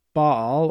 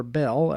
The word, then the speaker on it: Baal: Moses comes down off the mountain and sees the people living in sin by having created a statue of the wicked god Baal, or Bel, as some pronounce it.